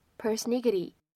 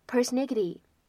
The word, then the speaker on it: persnickety: 这样一个单词呢，这是一个美式的拼写和发音，在美式英语当中呢，我们写成是 p e r s n i c k e t y persnickety persnickety persnickety。